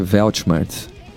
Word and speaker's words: Weltschmerz: Essa palavra se pronuncia Weltschmerz.